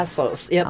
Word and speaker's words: Tres Ossos: Oh, Tres Ossos. Yep, Ossos, like three Tres Ossos.